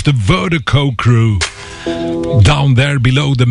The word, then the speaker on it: Virdiko: And Vertigo is spelled V-I-R-D-I-K-O. That's V-I-R-D-I-K-O. Pronounced Vertigo.